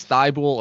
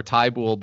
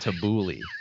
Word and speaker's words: Thibault: Uh, it is either Matisse Thibault or Tybull, but it is definitely not Tabouli.